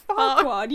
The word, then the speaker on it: Farquaad: Lord Farquaad. – You're going to say, like, Kumquat.